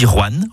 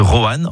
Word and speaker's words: Rouen: Vous avez remarqué comment je dis Rouen en une syllabe plutôt que Rouen en 2 syllabes ? C'est comme ça qu'on y dit dans la région.